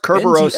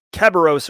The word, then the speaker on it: Kerberos: It's pronounced Kerberos, so don't say Kerberos or whatever he was saying in an interview.